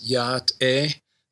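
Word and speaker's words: Yá'át'ééh: Ya'ate. Ya, yeah, Ya'ate, something like that. – Yes, Ya'ate. Ya'ate. – Sorry. – Uh-huh. That's much. Thank you for correcting that.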